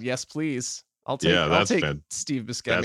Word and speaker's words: Buscemi: How do you pronounce his name? Steve. – Steve Buscemi. – Buscemi? – Yeah.